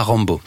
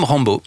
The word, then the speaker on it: Rambaud: c'est un Vendéen qui s'appelle William Rambo. Ah, ah. ça s'écrit R-A-M-B-A-U-D, hein, évidemment.